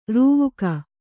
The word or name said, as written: ರೂವುಕ